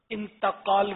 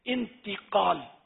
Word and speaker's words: انتقال: انتقال بولتے ہیں، صحیح تلفظ ہے انتقال.